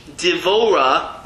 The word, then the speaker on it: Devora: Deborah in English. Devora in Hebrew.